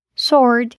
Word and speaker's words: sword: Outros exemplos de palavras com W não pronunciado são who, whom, whose, sword.